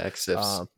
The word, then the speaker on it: Exif: the Exilfs, I'm completely butchering these names, the Exilfs. – Exifs. – Exifs, thank you.